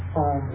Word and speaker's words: om: It's made of these three syllables, syllables, a-u-m. Syllables means the a-u is o, and then m. So we get om, not three syllables.